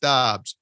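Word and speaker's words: Doubs: They lost their stud receiver, Romeo Rolling on Dubs, although I guess they say now it's pronounced Dobbs.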